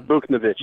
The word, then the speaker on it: Buchnevich: It's Buchnevich, you dick. – Buchnevich, and I can't say it. – Buch. – This is the worst podcast of all time. Buchnevich.